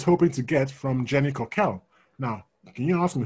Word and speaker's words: Cockell: Right, Jenny Coquel, which is Jenny, and then Coquel is C-O-C-K-E-L-L, and it's pronounced like that, Jenny Coquel, because I believe it's French.